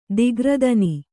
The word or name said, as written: ದಿಗ್ರದನಿ